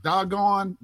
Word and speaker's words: Dogon: The Dogon, how do you say it? Dogon. The Dogon culture believes in female circumcision.